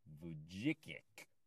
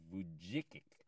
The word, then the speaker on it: Vujicic: Vujicic. I think that's the way you say his name. Nick Vujicic. It's V as in Victor, U-J-I-C. C as in Charlie, I-C as in Charlie. So it's V-U-J-I-C-I-C.